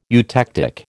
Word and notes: eutectic: American English

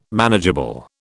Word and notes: manageable: British English

